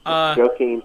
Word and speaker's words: Joaquin: Say walking. – Joaquin. – But don't say the G at the end of walking, and it's basically Joaquin.